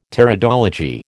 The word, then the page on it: Pteridology: American English